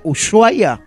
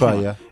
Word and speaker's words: Ushuaia: Esto de Ushuaia es que tiene la SH, ¿se pronuncia Ushuaia? – Sí. – Ah, bien, entonces lo dije. Ushuaia.